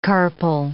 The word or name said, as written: carpal